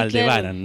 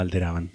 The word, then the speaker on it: Aldebaran: Alderaban. Hablando de Hitler. Aldebaran, no alteraban. siempre lo digo mal.